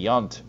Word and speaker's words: Yount: But I was talking about Robin Yount earlier because he was part of that All-Star squad in 1983, and he says that it's pronounced Y-U-N-T, Yount, Robin Yount.